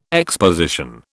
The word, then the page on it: exposition: British English